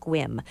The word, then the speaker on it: Sequim: And then if you want to have a real change, you stay in Squim, Washington, which is bizarrely spelled S-E-Q-U-I-M, but it's pronounced Squim.